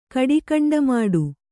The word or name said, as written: ಕಡಿಕಂಡಮಾಡು